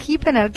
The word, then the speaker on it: Hipener: Hippener, la H va muda. – H-I-P-E-N-E-R.